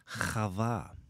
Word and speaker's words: Chava: The Hebrew word that is translated as Eve in the English text is Chava.